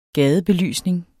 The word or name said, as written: gadebelysning